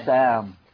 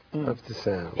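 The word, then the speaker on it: Ibtisam (ابتسام): Ibtisam. – Ibtisam.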